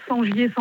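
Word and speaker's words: Sengier: Sur mon nom, Sangier Sandrine. Et il y a tout. – Sangier, ça s'écrit comment? – Alors, S-E-N.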